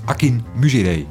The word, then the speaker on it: Akinmusire: Hij heet Akinmusire. Zo spreek je het uit. En niet op een andere manier.